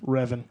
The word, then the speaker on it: Revan: Is it pronounced Revan or Raven, then? – Revan. – Revan, okay.